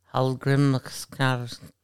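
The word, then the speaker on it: Hallgrímskirkja: Alessia Holgrimska. – Yep, exactly. That's exactly how you pronounce it.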